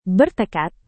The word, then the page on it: bertekad: Indonesian